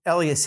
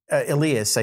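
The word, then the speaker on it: Elías: I know, like, Tony Elias has, Elias, I should say, now that I know how to pronounce it properly,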